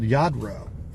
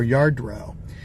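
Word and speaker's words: Lladró: One of the things was this thing called Yadro, or Yardro, and I didn't know much about it. It's spelled L-L-A-D-R-O, and it's a Spanish company made in the 50s.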